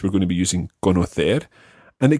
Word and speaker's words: conocer: All the infinitives always are stressed on that last syllable. So it's saber, conocer.